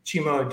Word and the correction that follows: Chuma: Adupe. Is that Chuma. – So, I think it's called Chuma.